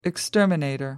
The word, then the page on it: exterminator: British English